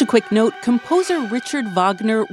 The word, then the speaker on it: Wagner: So I pronounce it Wagner because the the neo-Nazi who founded it was inspired by uh, the composer Wagner's professed Nazi sympathies.